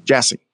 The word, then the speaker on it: Jassy: Amongst the benefits gained, Amazon president and CEO, Jassy? I said it right? – Jassy.